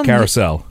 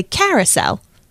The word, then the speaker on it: carousel: You say it so carousel. – You say it so beautifully. I accent a different part. I say carousel.